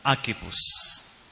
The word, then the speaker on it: Archippus: Archippus.